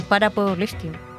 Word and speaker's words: para powerlifting: Claro, en para powerlifting.